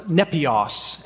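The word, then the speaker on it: nepios (νήπιος): In Greek, the, the actual word is pronounced nepios, and it's quite literally translated toddler, a toddler.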